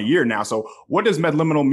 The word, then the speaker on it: Medliminal: When I started with the company, it took me 3 weeks to figure out how to pronounce Medliminal. Now I got it. It's Medliminal. M-E-D-L-I-M-I-N-A-L.